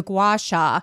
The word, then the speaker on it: gua sha: Well, gua- I've how do you pronounce it? Gua- it's a gua sha.